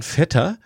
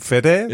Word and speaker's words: Vetter: Jan Vetter. Nur das Fahre in Urlaub als V-E-T-T-R, also wie der Cousin. – Nicht wie norddeutsch ausgesprochen Fette, sondern nicht wie Helga Feddesen, genau, ne?